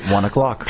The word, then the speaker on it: ONE OK ROCK: One OK Rock. It's three separate words. – But in Japan, they pronounce it 1 o'clock.